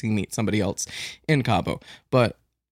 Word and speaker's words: Cabo: Am I saying that right? – Yeah, it's Cabo.